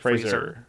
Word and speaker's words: Fraser: I'm saying the name right. Fraser, right? – Fraser. Mm-hmm. Fraser.